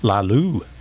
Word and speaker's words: Laloux: Laloux.